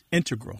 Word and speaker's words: integral: Just to clarify here, both correct, but the mathematicians and math-using scientists are more likely to put, say, integral with the stress on the first syllable.